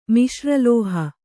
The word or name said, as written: ಮಿಶ್ರಲೋಹ